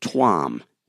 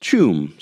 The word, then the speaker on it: Tuam: It was called "The Lost Children of," and I pronounced it Tuam, T-U-A-M, but the article says it's pronounced Chum, C-H-E-W-M,